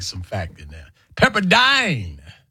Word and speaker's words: Pepperdine: Long Eye? Dine? Okay. Rhymes with fine and line. Okay, Pepperdine.